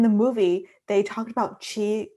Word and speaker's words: Qi: And Qi is, well, they I think in the subtitles, they spelled it C-H-I, but technically it's Q-I in Chinese pinyin, but it's pronounced C-H-I, so Qi.